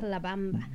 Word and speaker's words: La Bamba: B-A-M-B-A. – He just wants to be about a bomb. – No, Bomba. That's why you can say Bomba, because it actually says Bomba on the thing. – I mean, that's what I'm reading how it's spelled. – It's La Bamba.